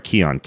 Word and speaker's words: Kion: It's all over at Keon, K-I-O-N, key, like prana, chakra, life force, energy. That's what key stands for, and that's how you pronounce it.